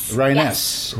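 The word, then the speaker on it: Ryness: Did I say his name correctly? – Reines. – Reines. Emphasis on the nest.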